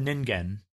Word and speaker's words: Ningen: The skin of this critter is pale white, like a fish belly, and is called the Ningen. The word Ningen means human or human being in Japanese. – That's not what I would name it. – It's spelled N-I-N-G-E-N, just for...